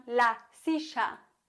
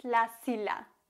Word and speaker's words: silla: Vorsicht, la silla wird mit Doppel-L geschrieben. Und Doppel-L wird im Spanischen als J ausgesprochen. Die Argentinier, die sprechen Doppel-L als Sch aus. Die würden also sagen la silla. Was du aber auf gar keinen Fall sagen darfst, ist la silla. Das wäre dasselbe Phänomen, das leider bei Mallorca sehr weit verbreitet ist.